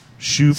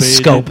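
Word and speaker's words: Schoop: Shoop. Shoop-a-doop. – I think they pronounce it Scope, though.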